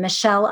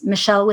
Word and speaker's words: Michelle: So it's just my full name, Michelle Icard, and it's Michelle with two L's.